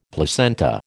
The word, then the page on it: placenta: American English